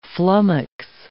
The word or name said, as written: flummox